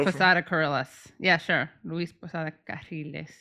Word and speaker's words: Luis Posada Carriles: Luis Posada Carriles. I'm not actually good at rolling my double R.